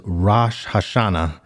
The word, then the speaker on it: Rosh Hashanah: I believe the proper pronunciation is Rosh Hashanah.